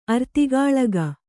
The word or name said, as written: ಅರ್ತಿಗಾಳಗ